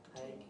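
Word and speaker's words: Haïk: H-A-I-K. – Hake? – Hake, and then Ventura.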